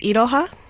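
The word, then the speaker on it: Iroha (いろは): Is it Iroha? Is that the way to pronounce it? – It's Iroha.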